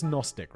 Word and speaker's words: Gnostic: Gnostic? It's just Gnostic, right? Silent G.